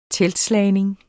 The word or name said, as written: teltslagning